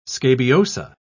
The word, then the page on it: scabiosa: British English